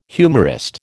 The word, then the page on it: humorist: American English